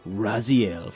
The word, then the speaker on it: Raziel: My Little Ponfar says I'd have to pick Raziel from Soul Reaver.